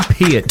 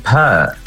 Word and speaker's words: Peart: Lee Peart, to rhyme with ear. Yes? – Oh, yes. Sorry. Well, some people say Peart. – Do people get it wrong often? – Peart. Oh, all my life. They say per.